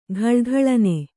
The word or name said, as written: ಘಲಘಲನೆ